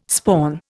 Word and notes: spawn: Indian

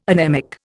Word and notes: anemic: Indian

